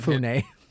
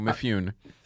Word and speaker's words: Mifune: Mifune. – No, Mifune.